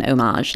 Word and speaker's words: homage: homage like this. Yeah, gonna just fly past the fact that you used the word homage with the French pronunciation there.